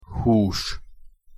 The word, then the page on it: hus: Hungarian